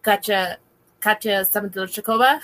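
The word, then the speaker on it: Katya Zamolodchikova: I also really enjoy Katya Samandilochikova.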